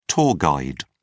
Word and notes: tour guide: British English

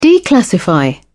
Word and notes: declassify: British English

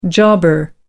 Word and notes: jobber: American English

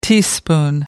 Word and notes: teaspoon: American English

